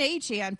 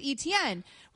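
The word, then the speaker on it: Achane: And so apparently, Achan, who was formerly known as Etienne, says that it's always actually been Achan, but we've always been pronouncing it wrong with Etienne.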